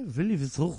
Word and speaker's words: Louis Vuitton: How do you say Louis Vuitton? – Louis Vuitton.